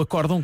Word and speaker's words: acórdão: Um deles é o acórdão, que tem vogal fechada na sílaba cor.